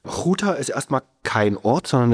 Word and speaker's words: Ghouta: Ghouta.